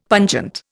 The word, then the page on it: pungent: Indian